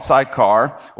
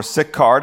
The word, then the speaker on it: Sychar: So he came to a town of Samaria called Sychar, or Sychar, depending on how you want to pronounce that, near the field that Jacob had given to his son Joseph.